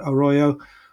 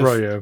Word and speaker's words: arroyo: So stone walkways and steps were built into something called an arroyo. I think I'm pronouncing that right, arroyo, which is a kind arroyo.